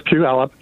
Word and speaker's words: Puyallup: It's pronounced Puyallup.